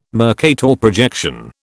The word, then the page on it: Mercator projection: British English